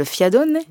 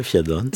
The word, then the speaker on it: fiadone: Dans les desserts fameux de Corse, on peut citer, attention à la prononciation, le fiadone. – Oui, ça va, le fiadone.